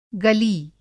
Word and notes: गली: گلی

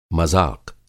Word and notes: मज़ाक़: مذاق

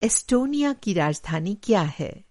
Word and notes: इस्टोनिया: استونیا

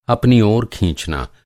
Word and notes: खींचना: کھینچنا

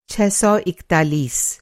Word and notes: छह सौ इकतालीस: چھ سو اکتالیس

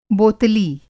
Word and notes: बोतली: Hindi

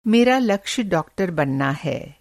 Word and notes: लक्ष्य: (لکچھ)لکشے

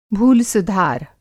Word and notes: भूलसुधार: भूल-सुधार

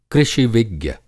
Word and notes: कृषि-विज्ञ: Hindi

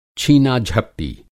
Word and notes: छीना-झपटी: Hindi

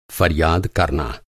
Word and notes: फ़रियाद: فریاد

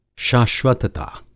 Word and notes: शाश्वतता: Hindi

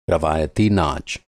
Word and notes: नाच: ناچ

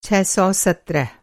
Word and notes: छह सौ सत्रह: چھ سو سترہ; ६१७

